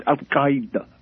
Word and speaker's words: al-Qaeda: First of all, is the indigenous pronunciation al-Qaeda? – It's al-Qaeda, actually, but that's a bit difficult for kind of the Western general tongue. So, yeah, al-Qaeda is a definitely good version of it.